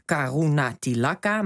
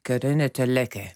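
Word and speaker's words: Karunatilaka: Je leest Karuna Tilaka, maar het is Karuna Tilaka, moet je geloof ik zeggen.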